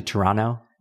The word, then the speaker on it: Toronto: I've been calling it Toronto the whole time. It's actually Toronto. – Toronto. – The T is silent.